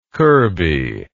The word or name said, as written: kirby